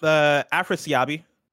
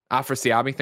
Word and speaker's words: Afrasiabi: Am I in the ballpark on this one? Afrasiabi? Am I saying that? How would you say this? – Afrasiabi. – Afrasiabi, thank you very much.